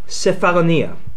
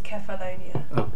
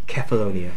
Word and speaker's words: Cephalonia: I pronounce this in my head as I was reading it, as Cephalonia. – It's Cephalonia. – Okay. Sorry. Okay. Cephalonia.